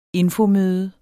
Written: infomøde

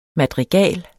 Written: madrigal

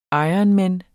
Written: ironman

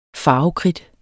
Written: farvekridt

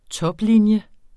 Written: toplinje

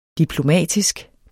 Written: diplomatisk

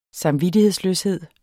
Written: samvittighedsløshed

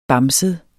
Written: bamset

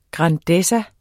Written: grandezza

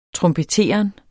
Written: trompeteren

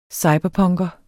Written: cyberpunker

